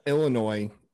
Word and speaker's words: Illinois: Also, the S at Illinois is silent.